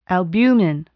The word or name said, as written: albumin